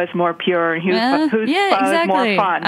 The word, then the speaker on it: pho: And I'm not sure if I'm saying it right. – I did? Pho? Okay. – Yeah, pho. You did.